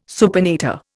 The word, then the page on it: supinator: Indian